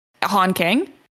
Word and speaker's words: Han Kang: On Kang, maybe? I'm butchering that. Mm-hmm. Han Kang? – Yeah, you got it right.